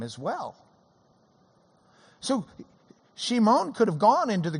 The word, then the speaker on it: Shimon: So he goes and he finds his own brother, Simeon, or Shimon in Hebrew is the way it would have been pronounced. Shimon, Simon, Shimon.